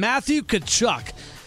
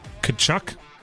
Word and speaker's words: Tkachuk: Tkachuk starts with a T? It sure does.